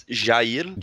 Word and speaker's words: Jair: It's Jair. Jair? Okay. Bolsonaro. Jair Bolsonaro.